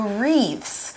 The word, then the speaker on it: wreath: Now, we have this word here, wreath, and it's kind of got an interesting spelling. We don't spell it with an R, we spell it with a W-R, and we only say the letter R. So it's spelled W-R-E-A-T-H, wreath.